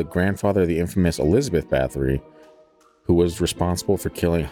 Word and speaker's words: Bathory: Is it Bathory? – Bathory. – Am I saying it wrong then? Bathory? It's almost like I'm pronouncing the O a little too much. Bathory. – Yeah, I think it's, I always hear it Bathory.